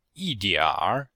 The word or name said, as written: yi4dianr3